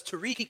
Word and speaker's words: Tarik: Saying the reason I would love it is because Tarik is the natural way I would pronounce it. So I can, instead of having to think about it all the time, I can just go with my natural pronunciation.